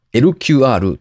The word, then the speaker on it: eL-QR: 決済におきまして、 2023 年 4 月 17 日より地方税の納付書に印字された地方税統一 QR コード LQR への対応を開始すると発表しました。小文字の E、に大文字の L、そして QR コードの QR、これで LQR と読むそうですね。